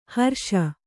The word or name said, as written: ಹ್ರೇಷೆ